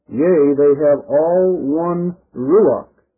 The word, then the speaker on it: ruach: And that Hebrew word translated spirit there is pronounced in English, ruach, ruach.